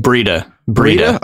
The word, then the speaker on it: Breida: Matt Breida, did we figure this one out? Breida, which one is it? – Breida. – Okay. – Rhymes with cheetah is what I was told.